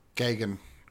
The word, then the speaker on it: Gaghan: Stephen Gaghan? Gahan? – Let me have a look. I've got pronunciations of Gagan or Gagan. – Gagan. Go with Gagan.